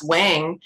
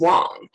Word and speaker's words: Wang: I mean, I think popular culture still think it's pronounced Wang. Yeah. And they say Vera Wang and Alexander Wang in Mandarin, which is my dialect, it's Wang.